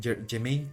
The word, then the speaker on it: Jemaine: Pero bueno, regresando a Taika Waititi, eh, junto con Germain Clement, que es mejor con Jemaine Clement, no sé si lo estoy pronunciando bien, ambos son neozelandeses.